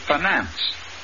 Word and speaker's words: finance: The word is finance. The I in the first syllable is short, and the accent is on the second syllable.